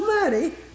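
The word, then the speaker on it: Mattie: Now, I don't reverence the name just because it's M-A-T-T-I-E and a capital M pronounced Maddie.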